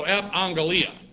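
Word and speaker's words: epangelia (ἐπαγγελία): Actually it's a double gamma in the Greek, A-G-G, but in rules of pronunciation, when you have these double hard consonants, the first one is sounded like a new. So epangelia.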